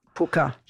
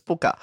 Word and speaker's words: pwcca: Some of it would say it's a jug of milk for the – now, it's spelt P-W-C-C-A, but apparently it's pukka. – Pooka, yeah. Pooka. – So I actually contacted Simeon, who got onto his Welsh relatives, who've confirmed that it's Pooka.